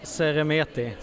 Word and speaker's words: Seremeti: Du, hjälp mig att uttala ditt efternamn korrekt. – Seremeti. – Seremeti. – Precis.